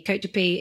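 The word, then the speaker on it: Côte de Py: Côte de Pie is literally, and when we say Pie, it's spelled P-Y,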